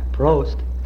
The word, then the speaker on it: Prost: Prost.